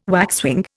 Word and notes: waxwing: Indian

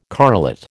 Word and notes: correlate: American English

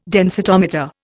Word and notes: densitometer: Indian

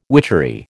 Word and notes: witchery: American English

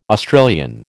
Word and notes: Australian: American English

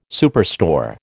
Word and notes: superstore: American English

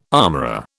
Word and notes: armourer: British English

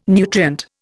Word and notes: nutrient: Indian

